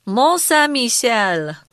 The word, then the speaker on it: Mont Saint-Michel: Mont Saint-Michel.